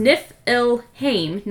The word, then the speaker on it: Niflheim: So Niflheim, it's also called it's pronounced Niflheim, not Niflheim, Niflheim.